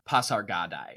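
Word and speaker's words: Pasargadae: I don't know why the audiobook narrator doesn't pronounce the E at the end. Like, that's just weird. – So you know what I'm talking about, where he says, calls it holy Pasargada. – I always say Pasargadai, but I started doing that before I had a lot of ancient Greek under my belt.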